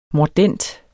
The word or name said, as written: mordent